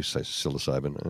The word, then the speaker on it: psilocybin: Psilocybin. – Thank you, psilocybin.